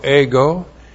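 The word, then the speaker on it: ego (ἐγώ): E-G long O, ego.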